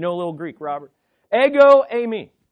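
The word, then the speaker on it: Ego, eimi (ἐγώ εἰμι): Just as Yahweh is important, here's how you say it in Greek. Ego, eimi. Let go of my ego. Ego, eimi.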